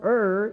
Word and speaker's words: err: which means the word err, it's not pronounced ear, it's err.